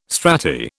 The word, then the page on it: strati: British English